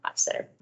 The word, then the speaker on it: Hochstetter: Hochstetter. – And Devon Hochstetter.